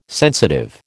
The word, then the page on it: sensitive: American English